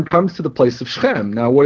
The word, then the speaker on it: Shechem: Now, some people pronounce that Shechem. It's Shechem.